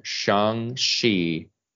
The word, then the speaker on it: Shang-Chi: And next time we're going to be talking Shang-Chi. Did I pronounce it right? – Yep.